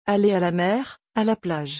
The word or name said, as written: Aller a la mer a la plage